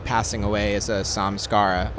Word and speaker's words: samskara: Is it Samskara? – So a samskara, it's a Sanskrit word.